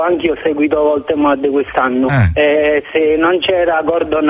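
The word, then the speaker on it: Woltemade: Voltemade, sì, si pronuncia così.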